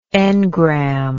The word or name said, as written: engrammatic